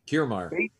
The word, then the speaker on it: Kiermaier: Kirkmeier. He had a Kiermaier. – How do you say it? – Kiermaier. – Kiermaier. Okay.